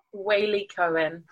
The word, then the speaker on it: Waley-Cohen: Waley-Cohen.